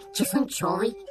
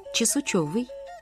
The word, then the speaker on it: чесунчёвый: Вот, например, такие «скабрёзный», «чесунчёвый», «идентификация». – Вот вы все слова произнесли неправильно. – Неправильно? – Надо так. «Скабрёзный», «чесунчёвый», «идентификация».